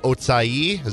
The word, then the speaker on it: Ochai: That would be Ochai Agbaji. – Correct as well. Would have also accepted the pronunciation of Otsai, as apparently that's how the how you say it, I guess, but I don't think anybody's going to actually call him that.